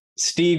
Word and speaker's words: Steeg: Stege with a hard G will suffice on that. But I'll go by Robert, Stege Life, whatever you want to call me at this point.